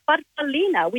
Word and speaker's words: farfallina: Well, growing up, as we're little girls, Mum called it a farfallina, which in Italian means a little butterfly.